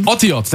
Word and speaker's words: Otiot: Otiot, that's how you say it. Okay. Yeah. – It means letters in Hebrew.